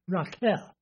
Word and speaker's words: Raquel: Or, you know, we have a movie star that pronounces that right. Raquel. Raquel Welch? Okay, spelled the same way, but she uses the Hebrew pronunciation, which were born to Jacob.